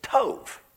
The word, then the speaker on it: tov: The Hebrew word translated good is tov. T-O-V.